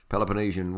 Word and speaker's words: Peloponnesian: Okay, moving on from the Peloponnesian Wars of ancient Greece. Did I say that right? – Yeah, you said that right, no worries.